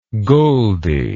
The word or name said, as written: goldi